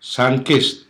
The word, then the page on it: sunkist: Hiligaynon